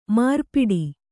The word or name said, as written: ಮಾರ್ಪಾಟು